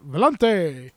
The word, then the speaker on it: Volante: Strymon announced the Volante, which I'm guessing that's how it's pronounced because it sounds Italian when I say it like that. Volante.